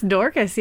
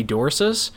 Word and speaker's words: Dorcas: It's Dorcas. Yeah, that's an actual name. – Okay, I'd never heard the name Dorcas before. I just thought it was like a soft C, maybe Dorcas. Okay, so it's Dorcas.